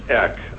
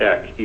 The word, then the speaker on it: Eck: And to the chair, my last name is pronounced Eck. I'm not like the town Eek in rural Alaska. My name is Eck, E-C-K. It's Norwegian.